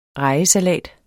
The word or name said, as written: REJESALAT